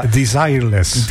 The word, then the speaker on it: Desireless: Het is een Desireless. – Desireless, ja. Ja. – Of Desiree, alleen zeg maar. – Het is eigenlijk op zijn Engels.